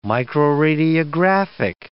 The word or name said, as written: microradiographic